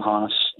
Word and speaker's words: Haas: And that's just under my name, Jim Haas, and two albums.